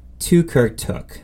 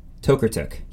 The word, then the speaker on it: Tokarczuk: My next one is Drive Your Plow Over the Bones of the Dead by Olga Tokarczuk. No, Tokarczuk. Yes, that's the right one.